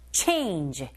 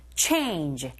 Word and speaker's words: change: change 끝까지 발음하지 마시고요. 최 에 이불의 강세로 꽉 주세요. change. change.